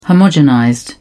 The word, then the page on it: homogenized: British English